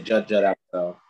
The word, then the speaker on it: Judd Apatow: There was a director, I think his name was Judd Apatow, or however you say his name. – Yeah, Judd Apatow.